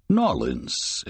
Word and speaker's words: New Orleans: Nawlins, as us locals say it.